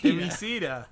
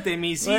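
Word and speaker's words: Themyscira: Temiskira. – Para mí era Temisquira, bueno, pero Temisqui. Me gustó tu pronunciación. – Bueno, lo pronuncié en latín.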